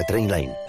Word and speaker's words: Trainline: Se escribe mejor precio garantizado. Se pronuncia Trainline.